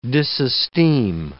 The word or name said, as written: disesteem